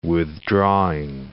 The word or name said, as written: withdrawing